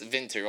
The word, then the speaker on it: Vinter: Our player, Adrian Vinter, it's pronounced Vinter, although it's spelled like winter,